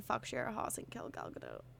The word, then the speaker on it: Gal Gadot: I've heard it pronounced different ways. I think it's technically Gal Gadot, right? – Gal Gadot.